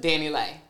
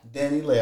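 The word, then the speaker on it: DaniLeigh: DaBaby and Danny Lay or Dania, what is it? – Danny Lay. – Danny Lay. I said it right the first time.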